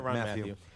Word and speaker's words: Tyrann Mathieu: Tyrann Mathieu, or I always say his name right. Everybody says his name right. You said it right. – I did, right? – Tyrann Mathieu.